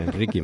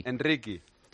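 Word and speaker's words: Endrick: Y lo que nos dijeron era que era Enrique, más o menos. – Enrique.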